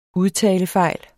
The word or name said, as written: udtalefejl